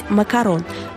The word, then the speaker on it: макарон: Исходя из оригинального названия, правильно говорить «макарон». А «макаруном» называют совсем другой десерт – кокосово-миндальное печенье в виде башенки, особенно популярное в Америке.